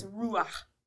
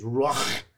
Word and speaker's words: ruach: I think of God's breath, you know, his ruach. – His ruach. – I'm trying to say it correctly. – Real guttural. Glottal. – Glottal stop.